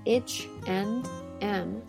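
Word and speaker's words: H&M: 首先，第一个呢，我们要注意啊，H and M 的发音，它的发音是 H and M。那么，如果呢，我们一个一个字读是 H and M，但没有人会这样读哦。 H and M 其实相当于呢，这个 and 和 m 连读了。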